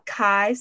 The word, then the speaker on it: Khai: It's Kai, spelled K-H-A-I,